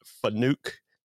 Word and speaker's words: Fanuc: They're called Fanuc. It's F-A-N-U-C America.